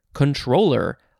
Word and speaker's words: comptroller: It's the word comptroller, C-O-M-P-T-R-O-L-L-E-R, but it does look like one of the approved pronunciations is just controller, like your video game controller, but that is not at all how it's spelled.